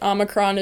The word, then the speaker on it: Omicron: It sort of is, but Omicron is the correct way to pronounce it.